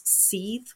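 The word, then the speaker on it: seiðr: If you want to read a really good book on the shaking practice, there's Jan Fries Seethe Ways, which is spelt S-E-I-D-R Ways, because it's the Norse Seethe practice, and that you get this blood flow going.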